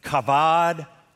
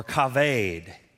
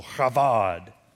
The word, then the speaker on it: kavod: The Hebrew word for honor is, and I know that's got a B, but it's pronounced with a V. It's kavod or kaved. And you got to have a little on there because it's Hebrew. So kavod.